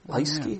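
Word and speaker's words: Lieske: Liesky? – Yeah.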